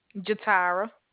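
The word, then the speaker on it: Jatyra: Jatara. – How do you spell that? – It's J-A-T-Y-R-A.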